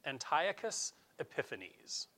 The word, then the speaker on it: Antiochus Epiphanes: It came from this guy, Antiochus Epiphanes. That's how you say his name.